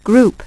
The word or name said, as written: group